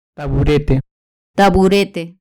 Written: taburete

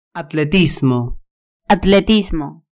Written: atletismo

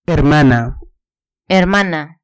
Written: hermana

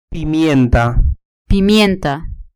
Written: pimienta

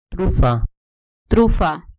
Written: trufa

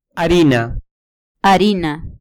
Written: harina

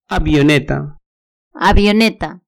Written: avioneta

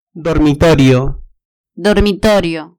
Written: dormitorio

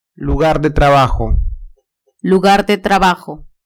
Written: lugar de trabajo

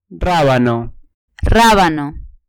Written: rabano

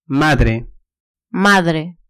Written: madre

